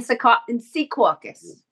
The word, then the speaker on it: Secaucus: We'll be in Secaucus.